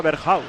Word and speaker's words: Berghuis: Nos dijo el otro día el compañero holandés que se pronunciaba Berghuis, ¿eh? – Es Berghuis.